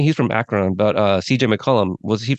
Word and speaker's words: Akron: Is it Akron, Ohio? Is that Akron. How you say it? Akron, Ohio. See, I knew it didn't Akron. Sound right.